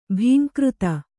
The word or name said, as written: ಭೀಂಕೃತಿ